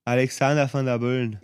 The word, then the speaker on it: Alexander Van der Bellen: Also ich finde wirklich ähm, Alexander van der Bellen ähm, Oder wie die Kärntner ihn aussprechen? – Alexander van der Böllen.